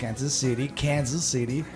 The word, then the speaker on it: Kansas City: Nativamente mia moglie è del Midwest, Kansas City, l'ho detto bene, did I say it right? – You sì.